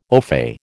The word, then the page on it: ofay: American English